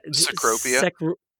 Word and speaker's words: Cecropia: So this thing's based on of an Atlas moth, or how do we say that? – Cecropia. – Cecropia, thank you.